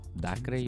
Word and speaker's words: Dacre: ¿Cómo lo pronunciarían? ¿Dacre? No. Dacre, yo. Dacre. Ahí yo leo Dacre.